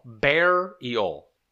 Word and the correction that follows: burial: Burial. Burial. – Burial. – I know that. Burial. – Mm-hmm. – You see the word in your head, B U R, burial.